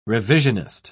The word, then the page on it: revisionist: British English